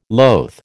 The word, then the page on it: loath: American English